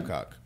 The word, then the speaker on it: Babcock: I think what happened was, however, Babcock, how you say his name? – Babcock. – Babcock took it is what made him get, you know, lose his job.